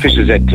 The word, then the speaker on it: Fischer Z: En hoe spreek je Fischer Z ook alweer uit, John? – Fischer Z. – Ja, gewoon Fischer Z, hè. Zo zei John Watert uit een keertje toen we hem dat vroegen.